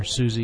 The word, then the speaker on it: SUSE: They might all be called Linux, but one's Ubuntu, one's SUSE, or SUSE as some people pronounce it, Red Hat, Yellow Dog, Slackware.